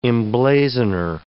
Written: emblazoner

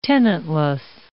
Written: tenantless

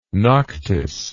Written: noctis

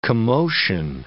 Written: commotion

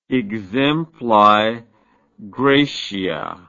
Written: exempli gratia

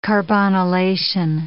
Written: carbonylation

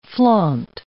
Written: flaunt

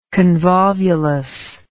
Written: convolvulus

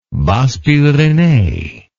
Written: Basses Pyrenees

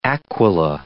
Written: Aquila

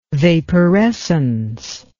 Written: vaporescence

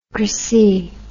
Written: cracy

